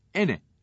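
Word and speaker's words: ene (أَنَا): Misal, Kafirun suresinde, Ena kelimesinin altında kasr yazar. Bu kelime ene diye okunur.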